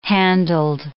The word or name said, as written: handled